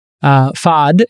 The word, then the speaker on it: Fahd: And Fahd is, and it's spelled F-A-H-D if you're wondering, is a general partner at Roach Capital.